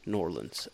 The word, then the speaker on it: New Orleans: Some people call it Norlands. Norlands. That's kind of how they say it properly, Norlands.